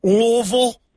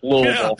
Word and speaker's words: Louisville: If you're from here, though, you pretty much drop the I and the S part of it, though, in the Louis. So it's like Louisville.